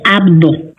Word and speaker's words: Abdo: el director del deporte, como es, a ver si lo puedo pronunciar, José Abado, ¿es correcto? – Bueno, José Abdo. – Abdo, con la B, Abdo.